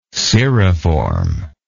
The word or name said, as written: cirriform